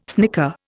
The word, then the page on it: snicker: Indian